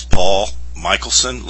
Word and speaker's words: Michelsohn: My name is Paul Michelson. Last name is spelled M-I-C-H-E-L-S-O-H-N.